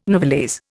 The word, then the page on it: novelese: Indian